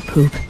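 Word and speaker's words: Poope: Boston Freedom Trail reenactor Jeremiah Poop, the E is silent.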